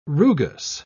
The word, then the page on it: rugous: British English